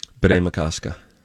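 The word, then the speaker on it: Bde Maka Ska: It's the spelling that's hard for me. – Spell it. – BDE space, yep. Capital MAKA space, capital SKA. – Bede Makaska. – Thomas Beach.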